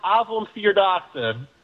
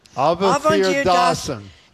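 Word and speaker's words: Avondvierdaagse: It's pronounced Avonvierdaagse. – Avonvierdaagse.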